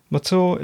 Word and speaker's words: Mathur: Matour is spelt M-A-T-H-U-R.